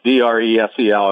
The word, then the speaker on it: Dresel: There's one S in Dresel, so Dresel.